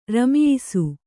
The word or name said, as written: ರಮಿಯಿಸು